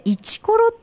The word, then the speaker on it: 156: はい、これ私も前は通ったことあるんですけど、 156 って数字で一コロって読むんですね。 – はい、そうなんです。一コロと読みます。